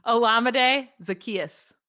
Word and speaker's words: Zaccheaus: Olamide Zaccheaus.